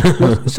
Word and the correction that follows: Socceroos: Socceroos. Ajá. Socceroos. Ah, bien.